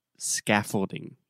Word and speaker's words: scaffolding: But before we do that, let's take a quick look at the pronunciation of the word scaffolding. – Scaffolding. – Yes, the stress is on the first syllable, and that first vowel sound is the ah, very open ah, like cat, hat, master, scaffold, scaffolding.